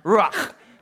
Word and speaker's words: ruach: Now, you got to say it like you got something stuck in the back of your throat, like ruach.